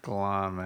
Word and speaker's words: Guillaume: Robert Guillaume. I don't even know how to spell that. – G-U-I-L-L-A-U-M-E.